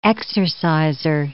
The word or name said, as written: exerciser